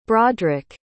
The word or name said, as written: broderik